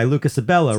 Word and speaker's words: Lucas Abela: Finally, an album called Gagu by Lucas Abela, Ruli Shabara, and Ramberto Agozalie. – Three... – Did I say that right? You gave me a nod of approval.